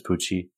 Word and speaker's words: Pucci: But to Lou Taylor Pucci, I hope it's Pucci. I'm pronouncing it because it's spelled like Tucci, but with a P, like Stanley Tucci.